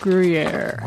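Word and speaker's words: Gruyère: Cave aged Gruyere.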